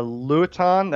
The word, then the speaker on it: Luiton: He's got a video up here on a 75-watt dual-band FM mobile, and I do not know how you pronounce that, L-U-I-T-O-N. – Luton, that's an older video, yeah. – Luton, the LT-598, you know, and he goes from that straight into some MFJ learning Morse code, CW.